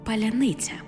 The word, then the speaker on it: паляниця: До речі, чому росіянам так важко вимовити слово «паляниця»? Погляньмо на фонетичну транскрипцію. Паляниця. Бачимо, що тверді м'які, а в окремих випадках й пом'якшені приголосні, ніби чергуються.